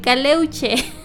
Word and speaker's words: Caleuche: Esta es la leyenda de El Caleluche. – Mm. – El Caleuche.